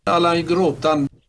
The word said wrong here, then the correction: grottan: Allan i gråtan. Alltså Allan i grottan.